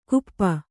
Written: ಕುಪ್ಪ

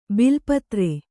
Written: ಬೆಲ್ಲಪತ್ರೆ

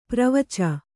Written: ಪ್ರವಚ

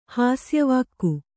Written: ಹಾಸ್ಯವಾಕ್ಕು

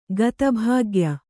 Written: ಗತಭಾಗ್ಯ

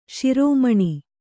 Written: ಶ್ರಮಣ

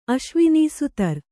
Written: ಅಶ್ವಿನೀಸುತರ್

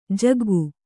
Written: ಜಗ್ಗು

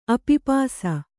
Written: ಅಪಿಪಾಸ

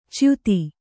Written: ಚ್ಯುತ

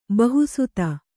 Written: ಬಹುಸುತೆ

